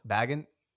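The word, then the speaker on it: Bagent: Is it Baggett? Baguette? – Baggett. Tyson Baggett.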